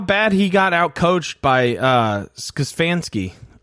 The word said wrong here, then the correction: Stefanski: I said that name wrong. Stefanski. Is that it? I always trip Stefanski? – Over that. – I think it's Stefanski, isn't it? – Stefanski? There, I said it right that time.